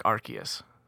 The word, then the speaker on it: Arceus: And its ability is called RKS system. Like the letter R, the letter K, the letter S. And it sounds like Arceus.